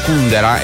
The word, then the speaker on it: Kundera: Ecco l'Antonello Venditti qui su Radio Latte, ne cita anche Milan Kundera e sulla U l'accento.